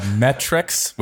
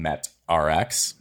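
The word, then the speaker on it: Met-Rx: No, a Metrix, which I only really recently realized that they were pronounced Metrix because I used to call them MetRx. But I think everybody does. – Right? Yeah. Yeah. It's like it doesn't naturally because it doesn't look like Metrix to me.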